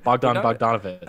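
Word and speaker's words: Bogdanović: Mr. Bogdanovich. I just pronounced that wrong, didn't I? Bogdanovich. – Oh, you got it.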